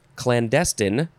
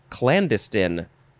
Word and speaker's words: clandestine: Next we have, okay, you can say this a bunch of different ways. Clandestine, clandestine, clandestine, or you can emphasize the first syllable, so it would be clandestine, and then also the different endings. Clandestine, I like to say, what do I like to say? Clandestine, I'll say that.